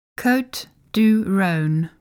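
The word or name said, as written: Côtes du Rhône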